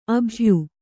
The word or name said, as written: ಅಭಯೆ